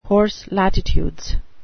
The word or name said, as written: horse latitudes